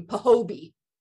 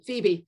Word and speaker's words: Phoebe: And my friend Kate, when she was in high school and they had to read Catcher in the Rye, she pronounced the female character's name Pahobie. – Pahobie? – Phoebe.